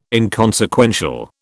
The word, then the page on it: inconsequential: British English